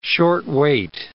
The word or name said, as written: short weight